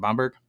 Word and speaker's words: Bamberg: All right, am I pronouncing that right? Bamberg? – Sounds right to me.